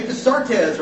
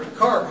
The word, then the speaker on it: Descartes: Or Rene Descartes or Descartes, who you're talking, depending on who you're talking to in pronunciation.